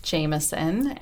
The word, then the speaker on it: Jamieson: My name is Jamieson, and my handle is at Jamieson Eileen.